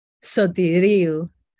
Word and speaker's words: Soteriou: Soteriou.